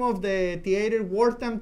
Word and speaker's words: Wortham: Se llama Wortham Theater. Se escribe W-O-R-T-H-A-M. Wortham, así, W-O-R-T-H-A-M.